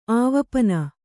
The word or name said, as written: ಆವಪನ